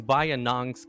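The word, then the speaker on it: Bayinnaung: Okay, I actually checked Ornlu's pronunciation of this just to make sure I would pronounce it correctly. But I can spell if you guys want me to. – Okay. – I can spell the name. It's B-A-Y-I-N-N-A-U-N-G.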